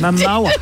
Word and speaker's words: Momoa: But you Momoa. – Yeah. – Think of Moa, mowing your lawn.